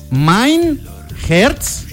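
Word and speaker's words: Mein Herz brennt: Entonces, esta canción se pronuncia Mein Herz. A ver, repita de mí. – Mein Herz. My Herz.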